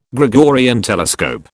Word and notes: Gregorian telescope: British English

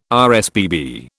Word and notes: RSPB: British English

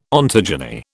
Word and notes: ontogeny: British English

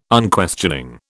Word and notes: Unquestioning: British English

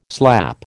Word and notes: slap: American English

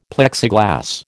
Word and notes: Plexiglas: American English

